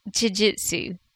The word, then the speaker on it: jiu-jitsu: You could try and pronounce it like the Brazilians. Jiu. – Jiu. – Jitsu. – Jiu Jitsu. Yeah.